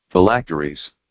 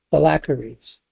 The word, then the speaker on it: phylacteries: Phylacteries. – Phylacteries.